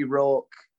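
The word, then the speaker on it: Rawk: So you can find Scotty Rourke on Twitter, Instagram, and Facebook. It's plain and simple, at Scotty Rourke. That's R-A-W-K. Please don't pronounce my name as Scotty Rock.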